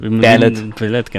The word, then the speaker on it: Ballet: Und reimt sich auf Array. – Ja, weil das anders ausgewählt ist. Ja, französisch ausgesprochen okay. Wird. Und die Ballet, genau.